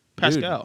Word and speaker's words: Pascal: Paschal? – Is it Pat? Oh, from the Warriors. – Yeah, the Warriors. Pascal.